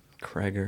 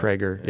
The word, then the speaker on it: Krieger: Ronnie Krieger. – Craiger. – It's really hard to say his name like that. – Craiger.